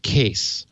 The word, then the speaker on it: Kees: He spells his name K-E-E-S, but it's pronounced Case.